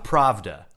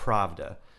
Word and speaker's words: Pravda: Pravda. – Pravda. – I believe it's Pravda.